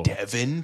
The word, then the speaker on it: Devin: Devin Townsend. – But it's spelled differently? – No, it's spelled the same. – It's not Devon. – It's like Devin, like Devin Sawa.